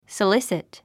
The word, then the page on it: solicit: səlísit; ソリスィット